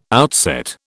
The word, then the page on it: outset: British English